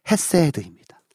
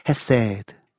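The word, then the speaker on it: 헤세드입니다 (חסד): 하나님의 성품 중에 무엇을 노래하냐면요, 하나님의 인자하심, 히브리어로 헤세드입니다. 저를 따라 한번 발음해 볼까요? 헤세드.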